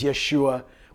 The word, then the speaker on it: Yeshua: That's just our modern pronunciation, our anglicization of a Greek way of saying a Hebrew word, which was Yeshua, which means the Lord saves.